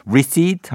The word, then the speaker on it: receipt: R E C E I P T입니다. 헷갈릴 수 있어요. 여기 P가 묵음이에요. receipt 하면 됩니다.